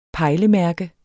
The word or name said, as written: pejlemærke